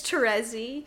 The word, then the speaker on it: Terezi: It's Terezi. But yes, that is correct. – Yeah. – Don't at me, anybody. I have a lot of feelings about Terezi Pyrope.